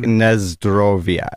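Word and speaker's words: Nazdrovia (на здоровье): It's like Nazdrovia. Like Nazdrovi. – Nazdrovia. – Like N-A-Z-D-O-R-O-V-I-A.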